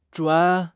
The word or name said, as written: zhuan3